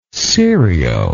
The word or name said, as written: cirio